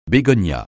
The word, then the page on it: bégonia: francais